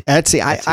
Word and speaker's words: Etsy: Well, the ETSI. Has laid Etsy. Out MEC Federation plans. – It's pronounced Etsy. – Etsy. I can't say Etsy like that. That's the website, ETSY.